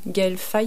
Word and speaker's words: Gaël Faye: On écoutera une chanson un peu plus tard de Gaël Faye, je ne sais pas comment on prononce.